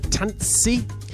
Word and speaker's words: Tanssi: Like, for example, in the album name, T-A-N-S-S-I. But you kind of have to go Tans... And, well, can I separate it? Tans and then C, and then take out the gap. So Tans C. So you pronounce both S's.